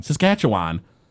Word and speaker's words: Saskatchewan: And I was like, okay, Saskatchewan can't be the Rough Riders, right? No, they absolutely are. It is the Saskatchewan? – Saskatchewan. – Saskatchewan.